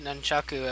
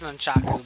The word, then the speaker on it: nunchaku: In fact, Archie even pronounced it nunchaku at one point. It's nunchaku, but it was close enough to me.